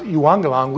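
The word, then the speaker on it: euangelion (εὐαγγέλιον): Glad tidings, those two words together in the Greek are actually pronounced euangelion.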